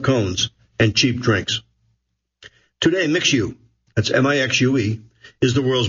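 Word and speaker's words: Mixue: And competitors are always nipping at their heels, particularly for MixU, where a sluggish Chinese economy has led to cutthroat price wars. Last year, MixU, pronounced Mixu, assembled managers and franchises in Singapore, said people familiar with the gathering, having expanded across Asia and Australia.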